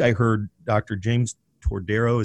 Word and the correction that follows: Todaro: is that how you pronounce Todaro. – His name? Todaro. Yeah.